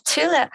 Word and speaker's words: Thule: So perhaps, I think it's pronounced Thule is a good company to start with. They bike racks and things. – Quite. Yeah, Thule, it's a great company for me.